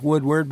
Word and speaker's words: Wudeward: And by the way, that's spelled, it sounds like Woodward, but it's spelled W-U-D-E-W-A-R-D. – So that's correct. – W-U-D-E-W-A-R-D.